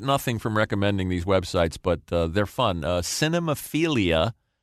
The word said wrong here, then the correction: Cinephilia: Boy, I added a syllable like Homer there. – Yeah. – Homer Simpson. Saxophone. Cinephilia and Beyond.